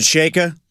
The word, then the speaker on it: Chayka: Is it Chayka? Is it, that's how you say it? – Yeah.